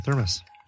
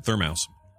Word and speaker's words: thermos: Brew it up at home, and then bring in a thermos. – Yeah, thermos. – Yeah, I'll do something. – My dad used to pronounce it thermouse. – No. – Yep. – Really? – Yep. He said thermouse.